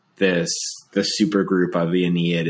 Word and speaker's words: Ennead: is the Ennead? Am I pronouncing that? – Ennead, yeah.